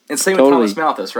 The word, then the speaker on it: Malthus: Thomas Malthus, yeah.